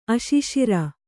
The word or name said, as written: ಅಶಿಶಿರ